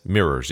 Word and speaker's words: mirrors: Yeah, that's mirrors, yeah. It's okay. It's two syllables.